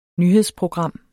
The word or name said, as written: nyhedsprogram